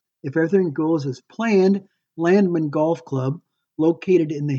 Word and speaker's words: Landmand: Landman, pronounced Landman, is Danish for farmer, and it's spelled L-A-N-D-M-A-N-D.